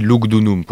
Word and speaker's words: Lugdunum: Donc on dit Lugdunum pour dire Lyon, mais officiellement c'est écrit Lugudunum qui signifie apparemment,